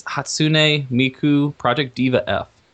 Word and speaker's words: Hatsune Miku Project Diva F: It's Hatsune Miku Project Diva F. – You got it.